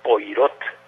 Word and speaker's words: Poirot: se escribe Poirot, es una eh, apellido francés y se, se pronuncia Poirot, o en un francés así muy depurado sería Poirot.